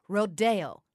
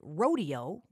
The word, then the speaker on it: Rodeo: Rodeo is how music scholars refer to the ballet. It does come from a Spanish word. Now, Copland occasionally called it rodeo when speaking casually, which is how we tend to talk about it in conversation. But for the record, the professional musician's pronunciation of this ballet is the original Spanish Rodeo.